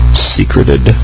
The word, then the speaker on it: secrete: No, you know, this actually says that both, for S-E-C-R-E-T-E, meaning to deposit or conceal in a hiding place, yeah. – Merriam-Webster says that it can either be secrete or secret. So it can still be pronounced secret, but it still has the E at the end.